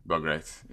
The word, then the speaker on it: Bogaerts: How do you say Bogaerts? Bogaerts? Is that what you're saying? – Bogaerts, yeah. Xander Bogaerts.